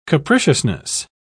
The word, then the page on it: capriciousness: British English